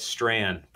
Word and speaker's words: Strachan: You say it Strand, but you never know that. – We all were wrong. – Yeah, it's a Scottish last name, and it's a Gaelic pronunciation of a Scottish last name. And so what that means in practice is nobody ever gets it right, because we don't speak Gaelish over here.